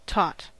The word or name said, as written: taught